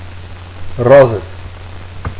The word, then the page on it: Roses: Catalan